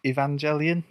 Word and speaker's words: Evangelion: There's in a lot of anime, they sort of a, particularly, you got to correct me on my pronunciation, Neon Genesis Evangelion, is that right? – Yeah, that's right.